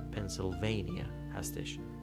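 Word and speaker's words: Pennsylvania: در انگلیسی به هیچ عنوان این شکلی تلفظ نمیشه و این پنسیلوانیا هستش پس آنچه ما میگوییم پنسیلوانیا آنچه آنان میگویند پنسیلوانیا آنچه میتوان گفت پنسیلوانیا عجیبه نه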